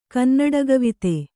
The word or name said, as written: ಕನ್ನಡಗವಿತೆ